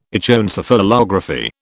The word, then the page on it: Echoencephalography: British English